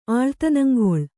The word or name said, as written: ಆಳ್ತನಂಗೊಳ್